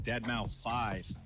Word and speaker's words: Deadmau5: This is your good friend Joel, professionally known as Deadmau5.